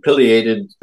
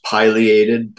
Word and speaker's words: pileated: And pileated can also be pronounced pileated, depending on where you are. But pileated is the most common pronunciation, I think.